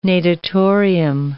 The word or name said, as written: natatorium